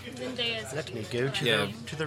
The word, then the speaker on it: Zendaya: Zendaya, yeah.